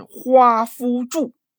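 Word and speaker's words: 华不注: 根据史料记载，这个华山原来叫华不住山，读音呢是花夫住，不是我胡说，好几代文人都频繁的给标注，但是大家还是念错。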